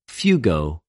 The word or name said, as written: φευγω